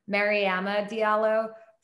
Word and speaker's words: Mariama Diallo: So it's oddly a little bit complicated of a pronunciation. I think that the distinction um, is a, a, little hard for people to hear. So you were close. You're very close. It's actually um, Mariama Diallo.